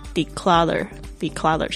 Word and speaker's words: declutter: Number 2， reduce， reduce 是减少 declutter 我忘记拼出来是 declutter clutter 那个 T 呢，有时候呢如果你听美式发音的话呢基本上会就是浊化变成介于 T 跟 D 之间的声音，就 declutter 像 water，因为它是夹在两个元音之间哦，稍微注意一下，这是英语的美式英语的一个潜规则，特别是发音哈。